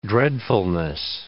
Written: dreadfulness

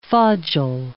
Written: fodgel

